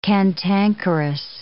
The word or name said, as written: cantankerous